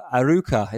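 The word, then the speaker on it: Arouca: Aruca, is that how you spell it? Is that how you pronounce it? – It is indeed, yeah, I think.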